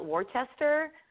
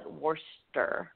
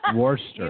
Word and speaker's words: Worcester: I call it Worcester, but I think in Massachusetts they call it Worcester. – Worcester.